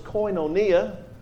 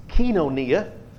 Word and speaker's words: koinonia: It's koinonia, or if you're speaking modern Greek, it's kinonia.